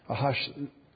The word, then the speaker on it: Ahasuerus: I think the New King James Translation uses Ahasuerus, is how you actually say it.